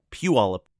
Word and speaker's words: Puyallup: I can pronounce it Puyallup, just outside Seattle.